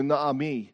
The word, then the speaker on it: Naomi: It's pronounced in Hebrew, Naomi. Naomi, because her name means pleasant.